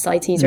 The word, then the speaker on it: CITES: Did I pronounce CITES right? Yeah. Is that how you pronounce it? Yeah? – You did well.